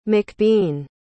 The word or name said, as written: macbean